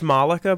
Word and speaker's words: Mollica: It's Malika, but having done the Edinburgh Fringe Festival, I understand that all the different accents, the ways to say Malika, so don't worry.